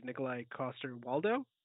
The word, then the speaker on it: Nikolai Coster-Waldau: For some reason, I would love to see, like, an older Link as Nikolai Coster-Waldau.